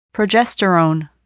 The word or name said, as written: progesterone